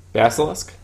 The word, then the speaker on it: Basilisk: How did you pronounce that? – Basilisk? – Basilisk? I gotta work on that.